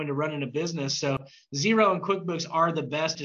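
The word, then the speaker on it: Xero: Zero, which is spelled with an X, it's X-E-R-O.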